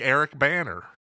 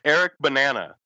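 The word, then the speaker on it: Eric Bana: Yeah, like Eric Banner. – Eric Banana is, I believe, how his name is pronounced.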